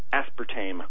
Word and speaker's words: aspartame: which is pronounced aspartame. – Oh, it is? – Yeah, A-S-P-A-R-T-A-M-E, aspartame.